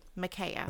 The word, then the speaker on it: Micaiah: The event is said to be witnessed in a vision of the prophet Micaiah.